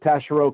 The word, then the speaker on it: Taschereau: Tell us about growing up in is that how you pronounce it? Taschereau, Quebec, Canada. – Correct. I got that right.